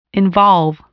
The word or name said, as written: involve